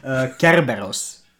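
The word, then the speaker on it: Kerberos (Κέρβερος): So, it's pronounced, in my good Greek accent, Kerberos. So, it's a bad translation where they replace really the R's with an L.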